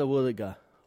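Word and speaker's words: Wuthuga: Our local Mulgana word today is Woodaga. What is a Woodaga?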